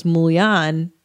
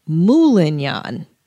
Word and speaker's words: moulignan: I thought it was Mulian, but he says Moulignan. Mm-hmm. He adds a syllable to it.